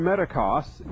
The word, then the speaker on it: metochos: We've said before that the term metakos, which is a singular, metakos or metakoi, better spell it in English, M-E-T-O-C-H-O-S or C-H-O-I, plural.